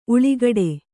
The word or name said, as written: ಉಲಿಗುಡು